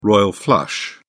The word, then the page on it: royal flush: British English